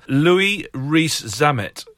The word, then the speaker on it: Louis Rees-Zammit: I know we've been panning Gloucester on this podcast, but Louis Rees-Zammit. I don't know if I pronounced his name right. – Yes, you did.